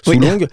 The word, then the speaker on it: Soulong: Ça s'écrit S-U, mais c'est bon, ça se prononce Sou. Soulong.